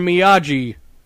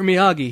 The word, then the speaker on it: Miyagi: The postman goes up and, delivers the letter, and he says, like, "Yo, Mr. Miyagi." And Daniel's like, "No, it's Mr. Miyagi." He's, like, very angry he mispronounced Miyagi's last name.